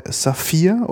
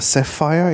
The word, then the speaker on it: Sapphire: Und sozusagen der klassische Bombay Saphir oder Sapphire, je nachdem, wie man es gerne mhm. aussprechen möchte.